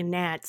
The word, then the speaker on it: gnat: So, I, I and yes, I meant to underst- say the G and, and the silent G and the gnat. Yes, I fully understand. – just like knife.